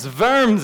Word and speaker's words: Worms: He said, no, it's a diet, which comes from Latin, but it's worms. It's a V sound with the W.